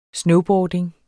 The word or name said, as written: snowboarding